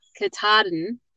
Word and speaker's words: Katahdin: Katahdin.